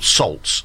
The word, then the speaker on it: Saults: And their band is called Salts, and never it's spelled heard of them. – S-A-U-L-T-S. – Does yeah. that mean something else? Or? – I ain't got a clue. – But as in assaults?